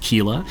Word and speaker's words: Keila: I think that I have the pronunciation of her first name correct, because on her Twitter feed, there are pictures of ways that people have misspelled her name on Starbucks cups, and it would suggest that it's pronounced Keila.